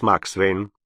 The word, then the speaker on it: Максвейн: Итак, Джон Андреас Максвейн». Один Фабель произносил эту фамилию правильно, на английский манер. «Максвейн». Остальные и Вернер Мейер в их числе произносили, как пишется, с твердым немецким «в».